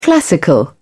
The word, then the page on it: classical: British English